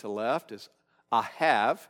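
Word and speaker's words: Ahav (אהב): And so the most common way of pronouncing this would be Ahav. The B on the end has almost more of a V sound, Ahav.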